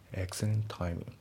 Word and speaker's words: excellent: で、あと発音ですけど、 excellent の T が抜けますね。 Excellent timing.